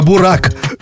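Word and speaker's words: Burak: Так, у нас сейчас будет «Буряк Йетер». – Ну, ты часто посещаешь Турцию. По-моему, это тебе должно без акцента вашего произносить. – «Буряк Йетер»? – Наверное. – «Буряк Йетер», «Йетер». – Не «Буряк», а «Бурак».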